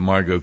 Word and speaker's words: Margot: If you're not on X, you should get on X just to look at what Margot Cleveland is saying. By the way, it's M-A-R-G-O-T. The T obviously is silent.